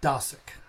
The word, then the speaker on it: Dosick: Radical Loving, One God, One World, One People, a book by Rabbi Dosick.